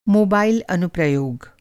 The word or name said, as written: अनुप्रयोग